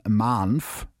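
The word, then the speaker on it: MANV: Um 18.07 Uhr gehen über über Funk die ersten Meldungen über einen Mahnf, so wird es wirklich ausgesprochen, habe ich extra nachgefragt, äh, raus.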